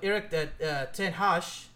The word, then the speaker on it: ten Hag: And since I'm skeptical, if they somehow win the Europa League with under the management of Erik ten Hag, which, by the way, that's how it's pronounced.